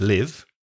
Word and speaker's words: live: L-I-V-E. – Well, it depends on the context, doesn't it? Because if it's a verb, it's live. I live in Valencia. We both live here. But if it's an adjective, it's live. So the vowel sound changes. Live, very short. Live, very long.